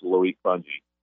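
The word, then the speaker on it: Loic Fouonji: It looks like an eye chart, but it's Loic Fungi.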